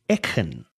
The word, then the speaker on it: Eachan: I got E-A-C-H-A-N, which is each with an A-N at the end. But that's not how you say it. And I've been trying to rack my brain around how to say it. And it seems as though it's something to the effect of Echen.